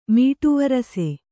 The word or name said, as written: ಮೀಟುವರಸೆ